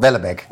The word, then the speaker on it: Houellebecq: Nee, Michel Wellebeck. Wellebeck.